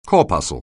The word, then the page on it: corpuscle: anglais